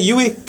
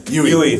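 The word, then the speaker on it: Yueh: Is that how we pronounce it? Yui? – Yui.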